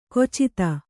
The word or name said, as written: ಖಚಿತ